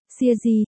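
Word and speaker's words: xia di (鞋子): Câu này nguyên gốc là, Si bu de xia di tao bô chơ lang, từ, xia di, Hán Việt vẫn đọc là, hài tử, nhưng nghĩa là, dày, hài, xia di, chứ không phải, con, hai di, bởi vậy nghĩa gốc của nó là, muốn bắt sói thì đừng tiếc giày.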